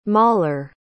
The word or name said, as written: mahler